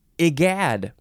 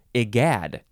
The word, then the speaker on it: egad: Now, I would want to say egad, I guess, but it says it's an I sound at the beginning. I, yeah, egad. You have to emphasize the second syllable. Egad. E-G-A-D.